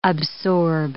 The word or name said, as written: absorb